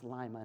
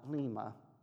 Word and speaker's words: Lima: I had a friend from Lima, and that's why I know it's pronounced Lima, not Lima.